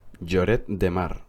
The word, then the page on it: Lloret de Mar: Spanish